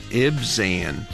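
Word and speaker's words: Ibzan: Ibsen. IB이기 때문에 Ib. Ib 그리고 Zen, Ibsen. 한 번 더요.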